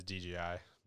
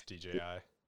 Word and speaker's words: DJI: I saw that they're using DJI or DJI, right? Not DGI. – DJI. I always pronounce it as DGI, but yeah, it's DJI.